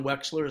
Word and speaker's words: Wechsler: Uh, John Wechsler is my name.